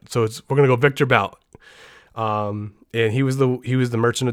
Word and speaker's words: Bout: This one is going to be about Victor Bout, or Butt, I guess it's pronounced in Russian.